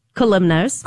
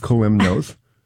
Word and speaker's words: Kalymnos: And how do you pronounce that? – Kalymnos. – Kalymnos. Or Kalismos or Kalymos. – Whatever you want to call it.